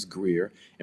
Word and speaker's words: Grear: I'm on Facebook, and it's James Greer, and my name is spelled G-R. It's on the screen, G-R-E-A-R.